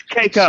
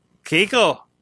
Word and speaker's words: Keiko: It's Keiko. – Sorg, like O'Brien's wife on DS9. Say it like her. – Keiko.